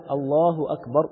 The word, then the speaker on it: الله أكبر: إحنا لو مدينا الهمزة في كلمة الله صارت هذه الكلمة استفهاما، يبقى إحنا هنا غيرنا المعنى تماما. فالشرط الثالث بتكبيرة الإحرام عدم مد الهمزة في الله. طب هينطقها إزاي؟ ينطقها بلا مد، الله أكبر.